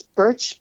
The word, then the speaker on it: Bertsch: And then the third one was put by a gentleman, a doctor named Tobias Birch, B-E-R-T-S-C-H. Is that how you pronounce it, Casey? – I believe so, yeah.